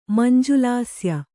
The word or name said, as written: ಮಂಜುಲಾಸ್ಯ